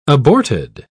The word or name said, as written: aborted